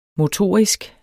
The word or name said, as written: motorisk